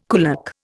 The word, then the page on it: kulak: Indian